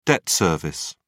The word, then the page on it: debt service: British English